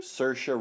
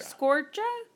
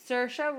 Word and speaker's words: Saoirse: How you say it? – Saoirse. – What? – Saoirse. – Saoirse? – Saoirse. – Scorcha? Saoirse? – Saoirse Ronan. – Saoirse Ronan. – Mm-hmm. – I, it's Saoirse.